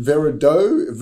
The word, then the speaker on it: Veridooh: Well, don't you pronounce it Veradoo? V-E-R-I-D-O-O-H? – Veradoo. that's one way of looking at it, but it's verifying digital out-of-home. So that's we'd like to say do.